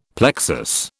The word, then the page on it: plexus: British English